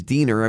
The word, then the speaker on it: Diener: Because, I mean, you know my name is Diener. Everybody calls me Diener. But when you see my name spelled out, I mean, it's D-I-E. N-E-R.